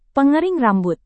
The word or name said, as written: pengering rambut